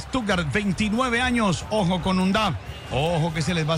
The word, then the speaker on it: Undav: Se escribe sin H. Un Duff. – Adelantado. Muy claro. Igual muy bueno. – Es decir, como un AT, pero no. – No. – UNDAV.